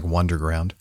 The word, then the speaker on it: Wunderground: Looks like Wonderground, spelled with a U.